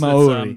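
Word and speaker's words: Māori: Maori. Yeah, it's Maori. They've got Maori. A different way of pronouncing the vowels.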